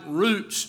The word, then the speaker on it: ruwts: But this word that is used here for post is the Hebrew word roots, spelled R-U-W-T-S, but pronounced like roots, just like we pronounce R-O-O-T-S.